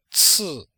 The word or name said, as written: ci4